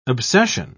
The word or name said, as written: obsession